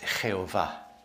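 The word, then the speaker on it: Jehová: And Spanish Bibles today and have always used Jehovah, which they pronounce Jehovah.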